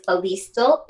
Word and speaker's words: Alyestal: And for me, my name is Alyestal. It is spelled A-L-Y-E-S-T-A-L.